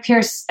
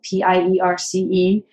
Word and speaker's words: Pierce: Uh, my my name is Magi Pierce. I spell it M A G I P I E R C E.